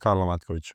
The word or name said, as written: karlo MATKOVIC